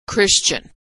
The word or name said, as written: christian